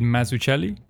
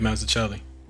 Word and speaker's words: Mazzucchelli: It's by Frank Miller, and I'm probably going to pronounce it badly, but David Mazzucchelli. – That's good. Dave Mazzucchelli.